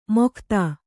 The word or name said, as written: ಮೊಕ್ತ